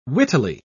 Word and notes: wittily: British English

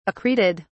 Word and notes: accreted: British English

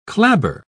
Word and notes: clabber: British English

